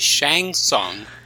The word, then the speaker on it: Shang Tsung: It's pronounced Shang Tsung.